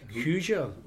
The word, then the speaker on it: Hoosier: Is that, did I pronounce that properly? Hoosier. – You did it perfectly, because you know what it means?